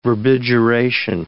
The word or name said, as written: verbigeration